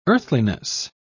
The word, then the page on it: earthliness: British English